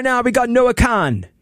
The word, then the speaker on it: Kahan: Because, fun fact, if you've never actually read this name on like a streaming service, right, you maybe listen to Noah Kahan's music. It's K-A-H-A-N. – Which is clearly Noah Kahan. – Yes, right? Kahan. Phonetically, it's Kahan.